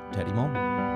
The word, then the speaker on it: Teddy Mohlman: So without further ado, Teddy Mohlman.